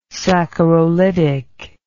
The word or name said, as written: saccharolytic